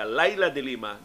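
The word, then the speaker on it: Leila de Lima: di ko moingon ko ninyo nga kining akong pronunciation nga Laila de Lima gikan ni mismo ni Laila de Lima na ang iyang spelling Laila de Lima pero ang actual pronunciation Laila de Lima.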